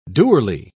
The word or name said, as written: dourly